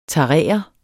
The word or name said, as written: tarere